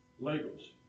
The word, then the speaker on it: Lagos: How do you say Lagos. – Lagos.